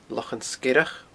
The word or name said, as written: Lochan Sgeireach